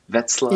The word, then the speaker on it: Wetzlar: Did I say that correctly? Wetzlar. – Yeah.